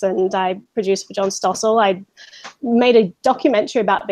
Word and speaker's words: Stossel: Stossel, yeah.